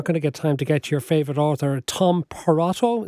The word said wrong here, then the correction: Perrotta: Is that how I pronounce it? – No, Tom Tom, Tom Perrotta, yeah.